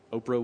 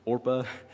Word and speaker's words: Orpah: People can't even get her name right, right? Oprah was supposed to be named Orpah.